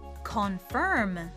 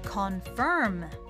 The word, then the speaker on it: confirm: Confirm Confirm Sounds great! 今回は最後に IRM が続いているので e r m というちょっとややこしい発音ですが口の形と下の丸め具合を気にしながらもう一度やってみましょう Confirm Sounds great!